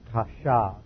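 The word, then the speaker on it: chashav (חשב): The original language, we have the words legizomai in the Greek, and then in the Hebrew we have the word chashav. C-H-A-S-H-A, and it with a bait without the dagesh is pronounced like a V, chashav.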